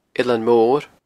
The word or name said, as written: Eilean Mor